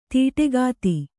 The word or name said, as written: ದಾಟುಕಥೆ